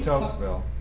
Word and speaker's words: Tocqueville: Tocqueville.